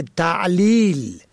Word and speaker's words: تعلیل: باید هر کدام را در مخرج خود تلفظ کرد و یا در تلفظ کلمه تعلیل ت عین لام یا لام علت آورنده را با کلمه تحلیل یعنی واضح بیان شده اشتباه می کنن.